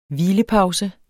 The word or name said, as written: hvilepause